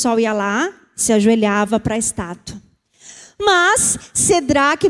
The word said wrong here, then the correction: Sadraque: Mesaque Estou falando certo? Sedraque, Mesaque e Abednego. Como é que é? Sadraque. De onde que eu estou falando isso? É que eu escrevi, tá vendo? Escrevi errado, falei errado. É Sadraque.